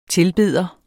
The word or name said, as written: TILBEDERNE